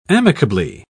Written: amicably

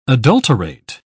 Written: adulterate

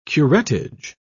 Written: curettage

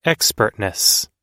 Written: expertness

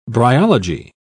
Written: bryology

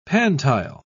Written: pantile

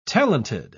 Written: talented

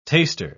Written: taster

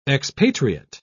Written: expatriate

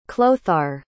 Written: chlothar

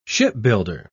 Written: shipbuilder

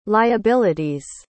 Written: liability's

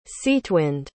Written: Cetewind

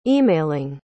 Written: emailing

